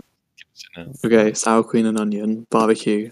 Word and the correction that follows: sour cream: Sour Cream.